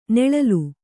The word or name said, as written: ನೊಳಲೆ